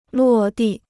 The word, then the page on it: 落地: luò dì